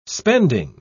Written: spending

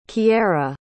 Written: kierra

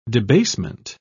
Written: debasement

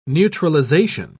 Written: neutralization

